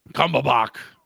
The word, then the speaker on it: Cumberbatch: Cumberbatch.